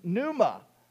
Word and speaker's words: pneuma: And it shares the same root for another word that means wind, pneuma, that also has a silent P at the beginning.